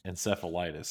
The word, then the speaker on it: encephalitis: What exactly is viral, I'm going to say it wrong, encephalitis? So is it? – Yeah, that's how it's pronounced.